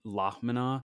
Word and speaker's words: lhamana: and it sounds like there's a few different pronunciations, like Plahmina or Zahmina, but Lahmina seems to kind of just be this, like, also generally accepted.